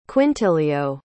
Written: quintilio

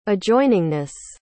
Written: adjoiningness

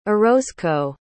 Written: orozco

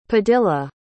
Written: padilla

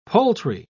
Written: poultry

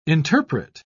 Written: interpret

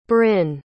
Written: brynn

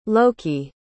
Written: loki

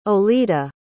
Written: Oleda

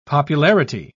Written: popularity

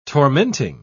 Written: tormenting

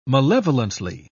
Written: malevolently

